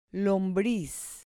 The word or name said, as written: lombriz